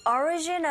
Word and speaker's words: origin: 나머지가 모두 다 내용어인데, origin, 오리진이 아니고 origin. 자, 앞에 철자 O의 강세가 딱 갔어요. 이런 경우에 O 소리보다는 I에 가깝게 발음이 들린다는 거 이제는 아시죠?